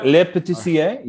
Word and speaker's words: Le Patissier: And speaking of building on something, so the PCA exclusive that you guys did release was a second cigar, which was the Le Patissier. Yep. Did I say it right? – Yeah, le petit sier. Yeah, you can say it with a French accent.